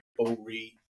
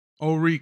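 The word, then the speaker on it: Horry: And my county, you pronounce the county correct as well. It's kind of like O'Ree. – O'Ree County.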